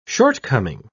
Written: shortcoming

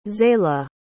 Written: zayla

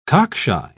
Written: cockshy

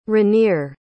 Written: Ranier

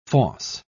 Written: fosse

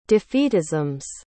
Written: defeatisms

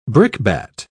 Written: brickbat

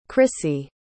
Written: crissy

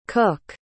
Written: cooke

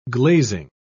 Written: glazing

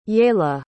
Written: yaella

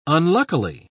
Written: unluckily

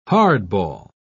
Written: hardball

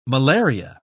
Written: malaria

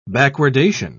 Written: backwardation

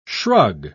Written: shrug

